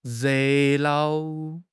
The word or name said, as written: re4 nao5